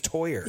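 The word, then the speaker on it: Theurer: His name is Toyer. Yeah. I always pronounced it Tour. So Toyer. Okay.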